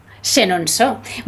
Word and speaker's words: Chenonceau: Bueno, fuera de antena me has dicho que no debería perderme el castillo de Chenonceau. – Chenonceau, muy bien, muy buena la pronunciación, ¿eh? – Bueno, genial, sí.